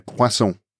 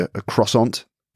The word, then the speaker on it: croissant: And what about the plain one? How do you say that word? – Une croissant. – Okay. And croissant. To a British person? – I don't know, maybe a croissant.